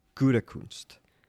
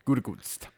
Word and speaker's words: Gutekunst: So we kind of want to call him Guten Tag, but it's just Gutekunst. – Gutekunst.